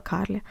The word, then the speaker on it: Карле: Часть глав этой книги рассказывает также и о Рольфе Карле, и, которую я упорно при прочтении почему-то назвала Карле, а, но посмотрела в оригинале, там все-таки стоит ударение, поэтому все-таки, видимо, он Карле.